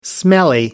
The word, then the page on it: smelly: American English